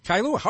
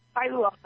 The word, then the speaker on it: Kailua: Is in Kailua, Hawaii? – Yes. – Did I pronounce that correctly? – Kailua. – All right. First time out. – Kailua.